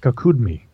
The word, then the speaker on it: Kakudmi: The Mahabharata and the story of King Kakudmi is how it's pronounced, but he describes going to heaven to meet Brahma.